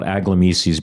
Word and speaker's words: Aglamesis: It is Aglamisis. It's phonetically exactly as you see it spelled, A-G-L-A-M-E-S-I-S, Aglamisis. And we have a lot of fun listening to it. Our customers try to come up with their pronunciations. A lot of people give it the French twist, Aglamisis.